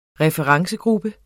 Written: referencegruppe

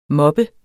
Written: mobber

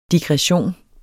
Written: digression

